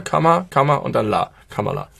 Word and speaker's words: Kamala: Aussprache einmal, ich habe es inzwischen gelernt, wir haben es häufig falsch gemacht, du gerade eben auch. Kamala Harris. – Kamala? – Ja.